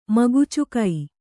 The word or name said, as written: ಮಗುಚುಕೈ